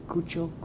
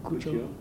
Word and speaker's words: Cuccio: How do you spell it? – C-U-C-C-I-O. Cucho. – Cucho.